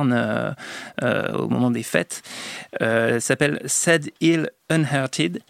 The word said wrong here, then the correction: Unearthed: Et c'est un documentaire. – Unearthed. – Ouais, je suis putain. En écrivant mal le truc, j'ai eu du mal à le reprononcer. Sadil Unearthed.